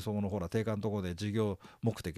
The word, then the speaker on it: 定款: これ定款って読む